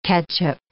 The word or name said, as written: catchup